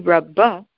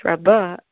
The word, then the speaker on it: Rabbah: So Rashi explains that whenever it's Rabbah, the adjective meaning great, the accent mark is on the second syllable. But here the accent mark is actually on the first syllable.